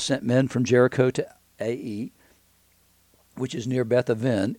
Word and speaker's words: Ai: And they returned to Joshua and said to him, Ai, by the way, is Ai, but it's pronounced Ai.